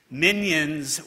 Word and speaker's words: minyan: But the Hebrew word minyan, pronounced minion in English and spelled with a Y-A-N, are the 10 Jewish adults required for communal worship.